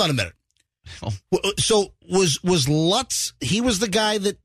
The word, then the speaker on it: Lutz: Yeah, the guy, Mr. Lutz, not Lutz. – With the beard. – spelled the same way, by the way. But the dude that killed his whole family. – George. – George Lutz, spelled the same way as Lutz here in the Tampa Bay area.